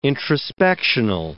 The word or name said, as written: introspectional